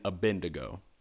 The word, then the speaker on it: Abednego: I'm a butcher it, but I know how to say it. It's Shadrach, Meshach, and Abednego.